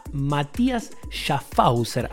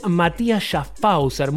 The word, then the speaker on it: Mathias Schaffhäuser: Hoy de invitado tenemos a Matías Schaffhauser, así se pronuncia lo que estabas leyendo en el banner en Twitter, Facebook, que decías, pero ¿cómo se pronuncia ese nombre de Ranch? Se pronuncia Matías Schaffhauser.